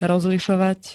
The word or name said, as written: rozlišovať